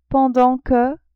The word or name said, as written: pendant que